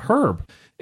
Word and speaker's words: herb: Like, there's a silent H there. My mom drove that one into me, pronouncing it herb, because I pronounced it her. I pronounced it how it's spelt, herb. And she's like, no, it's herb.